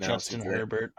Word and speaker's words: Herbert: Hubert, sorry, whatever. I'm not going to penalize myself for name Justin Herbert.